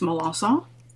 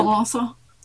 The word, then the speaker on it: Melancon: It's Melancon, and that makes my Cajun grandmothers very happy that I'm saying that correctly. – Wait. So there's, how did you say that again? – Melancon. – Oh, well, there's no C-O-N kind of sound to it at all?